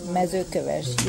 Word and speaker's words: Mezőkövesd: K-A-L-O-S-C-S-A. – And Mezőkövesd, that is the highland area. Mezőkövesd, you pronounce very nicely. Now, Kalotaszeg is Transylvania. – And that's the... – K-A-L-O-T-A-S-Z-E-G.